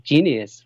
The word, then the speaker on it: Guinness: How do you pronounce that? Guinness, right? Guinness. – Yeah, right.